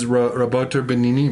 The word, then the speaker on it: Roberto Benigni: Is Roberto Benigni, am I saying that right? – Yeah.